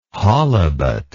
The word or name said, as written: holibut